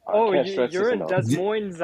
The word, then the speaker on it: Des Moines: Pronounced that, because it's French. I'm pretty sure it's French. Yeah. – I took French in high school, man. It's Des Moines.